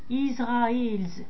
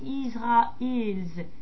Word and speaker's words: Israels: dem God Israel, Israels, avec un S, Israels.